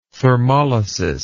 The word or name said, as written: thermolysis